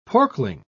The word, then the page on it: porkling: British English